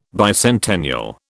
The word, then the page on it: bicentennial: British English